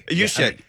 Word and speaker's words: Juszczyk: Juszczyk.